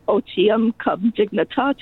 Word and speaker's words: otium cum dignitate: That people, I'm probably mispronouncing it. – Nope, that's pretty good. – Okay. That's not in my Black's Law dictionary. – otium cum dignitate, leisure with dignity, I guess, would be a rough translation.